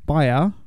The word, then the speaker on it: Bier: You've ruined my factual intro to Bird Box, which, Chris, is a 2018 film directed by Susanna Bier. B-I-E-R. Bier.